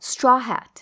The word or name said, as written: straw hat